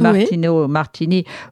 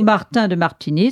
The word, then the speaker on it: Martino Martini: De Martino Martini ou Martin de Martini, ça dépend comment on prononce, à l'italienne ou à la française.